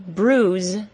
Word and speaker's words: bruise: Number two, bruise is the OO sound, like the word soon.